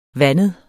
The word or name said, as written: vandet